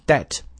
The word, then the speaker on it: debt: 后面的一个定语从句 that would force Germany to offer debt relief，那么强迫，这个危机一旦出现了，就会强迫德国不得不去提供啊这个债务的减免 debt 债务，注意 B 不发音 relief 啊减免。